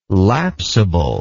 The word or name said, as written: lapsable